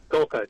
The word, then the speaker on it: Golka: You obviously had the wonderful leadership of Archbishop Aquila for many, many years, and now I believe it's Bishop James, is it Golka? Is that how you say his name? – Golka, that's correct. Yep.